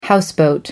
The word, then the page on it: houseboat: American English